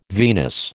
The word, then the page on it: venous: American English